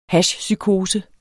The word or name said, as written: hashpsykose